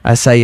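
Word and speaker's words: açaí: Es una fruta que tiene como una C, que se pronuncia como S. – ¿Aseca? ¿Acaya? – ¿Acay? – Nunca. Acai.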